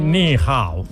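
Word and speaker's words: ni hao (你好): No sé si lo he pronunciado correctamente o no. – Sí, ni hao.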